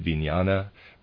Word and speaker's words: vijnana: I'm pronouncing it vijnana. Readers aside, it is spelled V-I-J-N-A-N-A.